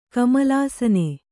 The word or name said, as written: ಕಮಲಾಸನ